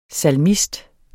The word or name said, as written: salmist